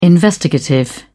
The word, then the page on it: investigative: British English